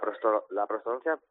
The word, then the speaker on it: prostodoncia: Bueno, a ver, la prostodoncia... – Prostodoncia, lo he dicho mal, ¿no? Prostodoncia. – Sí, es prostodoncia. Bueno, prótesis dental, prostodoncia, sí.